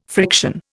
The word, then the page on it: friction: Indian